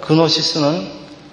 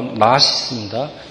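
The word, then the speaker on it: 나시스입니다 (γνῶσις): 여러분, 그노시스는 지는 무금입니다 그래서 지 발음 안 하고 나시스입니다.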